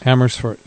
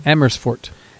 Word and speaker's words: Amersfoort: Amersfoort, yeah. – You know that town? – Yeah, that's where I've preached before. – Did I say it right? – Yeah, Amersfoort.